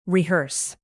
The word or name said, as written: rehearse